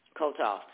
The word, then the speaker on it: Kotov: The Russian experts, including George Kotov, how you say that, K-O-T-O-V? Kotov? Hmm? – Yes. – How you pronounce it? – Kotov.